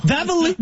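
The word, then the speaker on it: Daveluyville: Mais premièrement, ça se prononce Davrouville.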